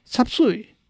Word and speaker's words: 杂碎: 插碎是杂碎这两个字广东话的发音，顾名思义是把猪肉、鸡肉、鸡胗加上豆芽、大白菜、芹菜切碎快炒，浇上汁的一道菜。